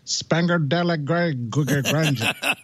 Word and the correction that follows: San Pellegrino: San Pellegrino.